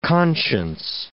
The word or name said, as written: conscience